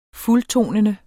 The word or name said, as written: fuldtonende